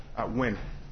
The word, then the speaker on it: Nguyen: Is that how he pronounced it? Nguyen? – Nguyen. – Nguyen? – Nguyen, as in W-I-N? – Yes, sir.